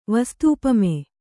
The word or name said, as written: ವಸ್ತೂಪಮೆ